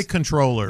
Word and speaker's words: comptroller: I didn't want to be the jerk, but every time you said controller, I was like, isn't it comptroller? – It's comptroller. Do you pronounce it as comptroller? – I say controller. – Yeah, yeah. – I think you can say it either way.